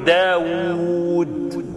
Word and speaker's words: داود: ما هو سيد داود احنا بنكتبها بواو واحده، وهي بتتنطق او بالاصل تتنطق باتنين واو. لما تقول داود دال الف واو واو دال، طاووس ط الف واو واو سين، ها؟ لكن احنا بنكتبها داود طاووس بواو واحده.